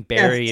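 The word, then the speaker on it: Barre: It's pronounced Barrie, right? Between Barrie, yes.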